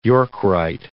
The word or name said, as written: York rite